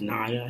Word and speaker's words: Niia: But the artist's name is N-I-I-A. – Okay. – It's pronounced Naya, I think. That's how it's pronounced.